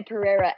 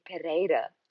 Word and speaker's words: Pereira: So yeah, in English we say Pereira, and in Portuguese it would sound better. It would be Pereira.